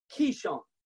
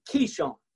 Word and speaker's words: Kishon: The Bible says that after defeating the false prophets of Baal, that they buried the dead bodies at a brook called Kishon. That is the exact Hebrew pronunciation, Kishon.